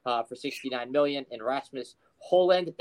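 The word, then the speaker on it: Højlund: Yeah, look, I mean, Hojlund, you don't pronounce the D. It's Hojlund.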